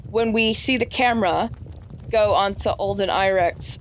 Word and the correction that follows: Ehrenreich: I just butchered his name. Sorry, it's 2:30 in the morning. Alden Ehrich, thank you. His face. – Alden Ehrenreich, that's what it is. Ehrenreich, that's it.